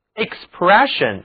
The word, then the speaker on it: expression: 我们来看表达，它的名词形式是 expression，也就是在 express 后面加上名词后缀 ion，这时候啊 sion 组合在一起读作 shen。 expression 就是表达、表露，还可以指人脸上所表露出的表情，比如 a sad expression a sad expression，那就是非常悲伤的表情。